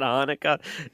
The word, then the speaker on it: Hanukkah: Pronounce this word here, but Happy Chinooka. – Happy Chinooka. It's an Hanukkah.